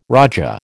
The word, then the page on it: raja: American English